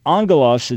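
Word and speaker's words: angelos (ἄγγελος): Angelos, it's A-N-G-E-L-O-S is the way we'd spell it. It's in Greek, it's gamma, gamma, so alpha, gamma, gamma, but the G-G becomes N-G. So angelos, and that's where we get angel from, is the word.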